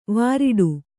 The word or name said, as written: ವಿರಾಟ